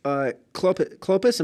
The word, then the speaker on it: Clopas: So it says, "But standing by the cross of Jesus were his mother and his mother's sister, Mary, the wife of Clopas." Am I saying that right? – Yeah, that's right.